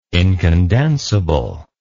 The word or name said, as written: incondensable